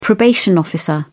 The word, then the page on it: probation officer: English; British English